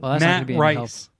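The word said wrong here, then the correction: Rhys: Reese. It's R-H-Y-S. – Ugh, that's annoying, right? – I think that's Reese.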